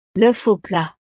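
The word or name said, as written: l'œuf au plat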